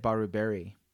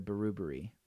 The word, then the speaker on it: Brewbury: But I always said Baruberry instead of Brewbury. But we're placing accents on different syllables there.